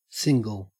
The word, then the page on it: single: Australian English